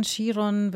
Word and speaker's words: Chiron: Chiron, Chiron wird unterschiedlich genannt. Ich bleibe bei der Aussprache Chiron.